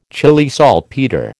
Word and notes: Chile saltpetre: American English

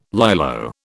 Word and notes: Lilo: British English

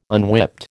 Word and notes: unwhipped: American English

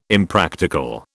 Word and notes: Impractical: British English

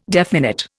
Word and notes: definite: Indian

